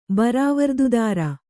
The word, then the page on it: ಬರಾವರ್ದುದಾರ: kannada